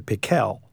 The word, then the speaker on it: Pickle: Actually, his name was Dr. Pickle, with the accent on the second syllable.